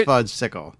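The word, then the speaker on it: Fudgsicle: But, like, for my dad's parents, it was always fudgicles. That was our go-to. And I know it's pronounced... It's not... Look... – First of all, it's fudge-sickle.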